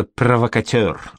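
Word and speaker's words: provocateur: По-французски оно произносится «провокатер», а?» «Я к этому проще отношусь, господин полковник», — ответил ротмистр Столов.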